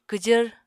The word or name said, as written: kwjvr